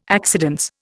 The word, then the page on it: accidence: Indian